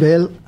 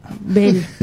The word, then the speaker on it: Böll: Es difícil de pronunciar. – Böll. – Heinrich Böll. – Böll. – Bueno, no estás en el alemán todavía.